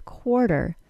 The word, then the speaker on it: quarter: For now, know that the letter T in the word quarter sounds like a quick D sound.